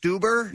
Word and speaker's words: Stuber: But he's been in a bunch of things as well, and he is promoting, I believe, is it pronounced Stuber? – Yeah, as in Uber, yeah.